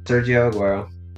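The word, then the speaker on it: Sergio Agüero: Sergio Aguero.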